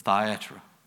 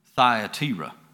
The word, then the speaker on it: Thyatira: 16 and 14, and a certain woman named Lydia, a seller of purple of the city of Thyatira. Actually, I pronounced that wrong. That's Thyatira is how that's pronounced.